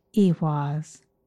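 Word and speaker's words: Ehwaz: Its name is pronounced Ewas, and it means horse or two horses, which are symbolic of transportation.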